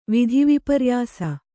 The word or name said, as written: ವಿಧಿವಿಪರ್ಯಾಸ